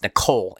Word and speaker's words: Niccol: Chipotle CEO Brian, uh, I guess it's pronounced Nicole, N I C C O L, he summed it up in a statement at the Baird Global Consumer and Technology Services Conference.